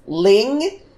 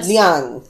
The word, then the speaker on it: Liang: Like, this episode, I was rewatching this episode, and I realized only for the first fucking time that what I thought was pronounced as Ling is Liang.